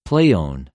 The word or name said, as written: πλειων